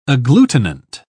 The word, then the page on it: agglutinant: British English